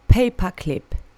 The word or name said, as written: paper clip